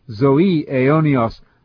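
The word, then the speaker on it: zoe aionios (ζωὴ αἰώνιος): And by the way, I'm pronouncing the Greek there as a modern Greek would pronounce it, zoe aionios, the life of the coming age.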